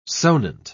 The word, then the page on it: sonant: British English